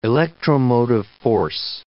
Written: electromotive force

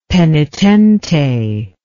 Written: penitente

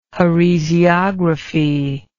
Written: heresiography

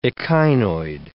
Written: echinoid

